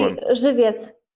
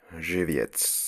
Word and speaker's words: Żywiec: It's probably Żywiec. – I'm willing to believe that that's what this is. – Is it Z with a dot? – It's a Zywiec. – Yeah, my old friend, Żywiec.